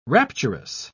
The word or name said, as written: rapturous